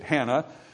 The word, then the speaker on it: Hannah: Now in chapter 1, we meet Hannah, Hanaz, it's actually pronounced, but we say it Hannah.